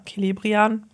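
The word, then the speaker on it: Celebrían: Also so Celebrian. – Genau. Oder ja, Celebrian, ja, so kann man es aussprechen.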